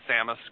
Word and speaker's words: Samus: Samus, yep, yeah.